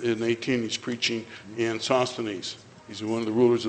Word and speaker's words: Sosthenes: Sosthenes, I think that's how it's spelled. Is that right? S-O-S-T-H-E-N-E-S, yes.